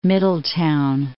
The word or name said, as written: Middletown